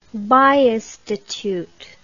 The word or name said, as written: biostitute